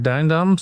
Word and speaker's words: Dujendam: Well, I'm Mark Dujendam. So, thank you for pronouncing my name really well. It's hard because in the Netherlands, sometimes there's a J within the name and then, but you shouldn't pronounce it.